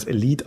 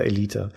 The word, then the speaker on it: Elite: Wir sagen der Vollständigkeit halber, eigentlich müsste man es Elite aussprechen, wenn man es vernünftig machen wollte, aber da scheren wir uns nicht drum, bei uns heißt es weiter Elite.